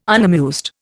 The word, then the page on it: unamused: Indian